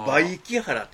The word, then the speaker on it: 梅木原: これ読み方が難しいんですよ。 – あ、梅木原って言うんです。 – あ、これ梅木原って読むんですか。